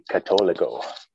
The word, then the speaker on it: Catolico: Technically, that's how I say it. It's technically Catolico.